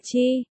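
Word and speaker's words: chi (吃): Chữ ăn trong tiếng Trung đọc là chi, cười nhạo lại là chích sao?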